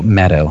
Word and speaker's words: Maddow: Maddow, I have to pronounce her name right.